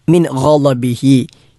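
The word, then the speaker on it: മിൻ ഗളബിഹി (مِنْ غَضَبِهِ): ല എന്ന് മുൻപല്ലിലേക്ക് നാവ് ചേർത്തുകൊണ്ട് ഉച്ചരിച്ചാലും, ല എന്ന് വാവ് ചേർത്തുകൊണ്ട് ഉച്ചരിച്ചാലും ആ ഉച്ചാരണം തെറ്റും. മിൻ ഗളബിഹി എന്ന് തന്നെ ഉച്ചരിക്കണം.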